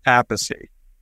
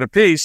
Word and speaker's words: Appice: Well, it's Appice. My father said Appice. And yeah. Carmine used to say Appice. And then when he started playing with Rod Stewart, Rod was confused how to pronounce the last name. And he pronounced it a piece, so Carmine went with a piece.